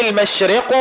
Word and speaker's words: المشرق: ومثلا قولنا ولله المشرق والمغرب لا بد من تحقيق كسرة الراء ونطقها مكسورة كسرا كاملا مثل نطق كلمة ريح.